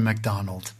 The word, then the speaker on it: McDonald: Alors, dis-moi un peu, Christopher, parce que ça ne se prononce pas comme ça, ton nom. C'est pas McDonald. Comment dis-tu ? – Christopher McDonald.